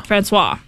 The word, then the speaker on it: Francois: How do you say it? Francois. Francois. That's yes.